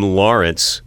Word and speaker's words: Larance: I was given the name Lawrence, which is spelled not traditionally with a W or a U. – L-A-R-A-N-C-E.